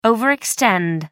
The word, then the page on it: overextend: American English